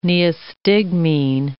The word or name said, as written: neostigmine